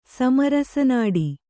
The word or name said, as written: ಸಮರಸನಾಡಿ